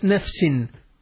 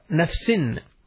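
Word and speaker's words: نفسٍ: And you can see here the word nafs, sometimes it is pronounced nafsun and sometimes it is pronounced nafsin. Sometimes it is with the dhamma, nafsun. And sometimes it is with the kasra, nafsin.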